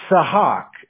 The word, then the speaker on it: Sahak: I was going to say, but it's Sahak. S-A-H-A-K, or you can even just call me Hawk, like H-A-W-K, that's fine too.